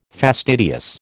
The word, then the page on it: fastidious: American English